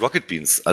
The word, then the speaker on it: Rocket Beans: Ja, das ist Rocket Beans. Also das ist da fehlt eigentlich das C, aber es sah so besser aus.